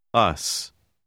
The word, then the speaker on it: us: Le S final se prononce S. Us. Us.